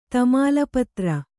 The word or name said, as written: ತಮಾಲಪತ್ರ